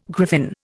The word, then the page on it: griffin: Indian